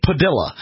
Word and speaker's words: Padilla: For whatever reason, you know, this family pronounces the name Padilla. And like Amarillo.